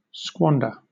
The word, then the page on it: squander: British English